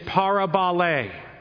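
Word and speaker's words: parabolē: The Greek word for parable is parabole. So it's just like parable, pronounced with an A sound at the end.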